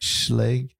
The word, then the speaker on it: Schlage: It was a Schlage. How do you say Schlage. – It? Schlage. – Yep.